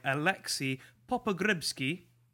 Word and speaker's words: Alexei Popogrebsky: I'm going to talk a little bit about a film by Alexei Popogrebsky.